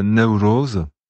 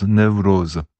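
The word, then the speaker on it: Newroz: Donc la fête c'est Névros, dans toute la variété ça se prononce Névros.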